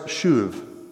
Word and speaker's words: shuv: Now it's pronounced shuv. I checked, right? You click a little audio button and it tells you this is how you say it. So I'm not like speaking Hebrew up here. I had to Google it myself. And so the word shuv, which literally means to turn.